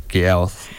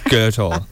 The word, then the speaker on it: Goethe: And then we had Sick Dogs Get Out Down by Goethe, which I had to look up how to pronounce that just before, and it's not how it's spelled at all. I've been saying Gyouth this whole time. It's Goethe.